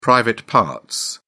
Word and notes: private parts: English; British English

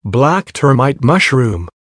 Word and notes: black termite mushroom: American English

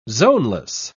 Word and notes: zoneless: British English

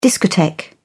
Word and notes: discotheque: British English

